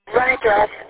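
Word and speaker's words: Rhyngress: Gary, and our last name is spelled R-H-Y, N is in Nancy, G is in David, R-E-S-S, music. – Hey, looking at it right now, Gary Rindress, is that how you pronounce it, or Rindress? – Rindress, and a lot of music that he did get it copyrighted, and it's out on Spotify, Pandora, 34 different streaming.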